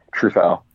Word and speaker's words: Truffaut: Truffaut.